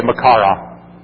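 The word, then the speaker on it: Machaira: It's the Makaria. M-A-C-H-A-I-R-A. I probably didn't even pronounce that right. In the Greek, it's Makaira. It's hard to say. But in the English, it's say Makara.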